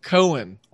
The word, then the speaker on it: Cohen: And don't forget, listeners, he is at It's Daniel Cohen on Twitter. Remember, there's a silent H in there. C-O-H-E-N. Cohen, like two chickens trying to exist.